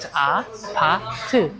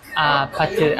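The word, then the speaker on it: a pa tu (아파트): It looks like you need to pronounce ew, ew. No. You should pronounce it like So it's a pa tu. Then repeat after me. A pa tu, a pa tu, a pa tu, a pa tu.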